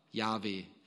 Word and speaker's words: Jahwe: J-H-W-H, Jahwe, wird es wahrscheinlich ausgesprochen. Wissen wir gar nicht sicher.